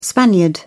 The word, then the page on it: spaniard: British English